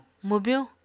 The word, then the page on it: mwbyuñ: Apatani